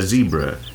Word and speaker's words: zebra: Yes, a zebra. To rhyme with Debra in Britain and Debra in America, once those countries exist.